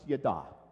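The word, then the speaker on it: yada (ידע): The word is just basically pronounced yada.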